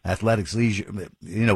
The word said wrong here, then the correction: athleisure: Athleisure, yeah, that's it, yeah, the ick is out of there. Just take the ick right out of that and then make things, yeah, athleisure, there we go.